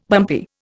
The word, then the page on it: bumpy: Indian